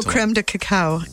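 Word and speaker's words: crème de cacao: And there is no creme de cacao in this one. – Is that how you say it, though? – That is how you say it. I was not wrong.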